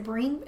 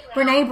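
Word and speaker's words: Brené: So you actually introduced me to the, is it Breen, is that how you say it? Breen Brown? How do you say that? Breen Brown? – Brene Brown.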